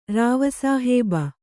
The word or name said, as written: ರಾವಸಾಹೇಬ